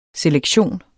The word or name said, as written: selektion